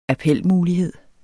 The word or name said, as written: appelmulighed